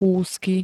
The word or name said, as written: úzky